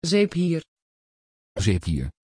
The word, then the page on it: Zephyr: Dutch